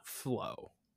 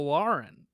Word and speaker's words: Folarin: And I'm like, but it's not flow, right? Yeah. It's Lauren.